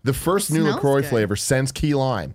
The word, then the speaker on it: LaCroix: LaCroix sparkling water, pronounced LaCroix, was developed to give health-conscious consumers refreshment flavor and a sparkle, blah, blah, blah, blah, blah.